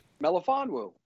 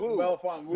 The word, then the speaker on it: Melifonwu: Melifonwu. That's his last name. – Melifonwu? – Melifonwu, sure about... I said. – Oh, Melifonwu.